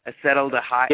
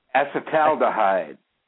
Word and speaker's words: acetaldehyde: Yeah, with an emphasis on the tal. Acetaldehyde.